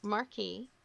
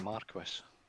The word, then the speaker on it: marquis: All right, so we're all heading to the court of the marquee, and or is it Marquis? – It's marquee. Thank you. We've had that discussion. We're using the American pronunciation.